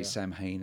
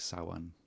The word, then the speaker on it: Samhain: I know they say Samhain in the movie, but it's Celtic. It's more like Samhain.